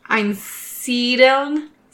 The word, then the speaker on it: Einsiedeln: Um, so he was born in 1493 in a Swiss village called Einselden, and oh, I suppose I'm, I'm, sure I said that wrong. – Sure. Okay. – Einsiedeln, something like that.